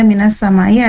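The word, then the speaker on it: minas samai (مِنَ السَّمَاءِ): Di sini ada minas samai, ada idgham syamsiyah atau alif lam syamsiyah, ya. Di mana huruf bunyi alif lam itu tidak terdengar.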